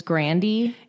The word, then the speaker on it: Grande: She was like, actually, it's Grandy. – Yeah, that's what I remembered. Okay. – I say Ariana Grande.